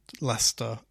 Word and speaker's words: Leicester: So Leicester is L-E-I-C-E-S-T-E-R. – Yeah, Leicester, just how I would write it. – Yeah. So for locals in Leicester, they pronounce it as if it's just L-E-S-T-A. Leicester.